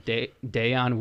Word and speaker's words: Dayon: Today I have Dayon Williams with me.